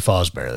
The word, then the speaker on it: Fazbear: Pretty much, but it's Fozzie's, I can never say it right, but anyway, Freddy Fazbear, that's it.